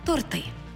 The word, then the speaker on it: торти: А торти чи торти? – У словнику наголосів української літературної мови 1959 року Миколи Погрібного читаємо на сторінці 539 «торти» і «два торти», а також «тортовий». Так само і в орфоепічному словнику 1984-го Миколи Погрібного на сторінці 566-й.